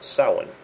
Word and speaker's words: Samhain: And of course, the one you're all familiar with is Halloween, which is pronounced Samhain.